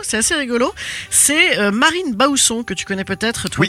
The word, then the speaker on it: Marine Baousson: Donc, Marine Bausson, exactement comme ça se prononce.